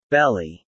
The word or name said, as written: belly